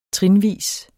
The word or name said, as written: trinvis